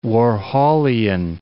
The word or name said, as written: Warholian